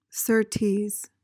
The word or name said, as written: certes